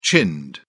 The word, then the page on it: chinned: British English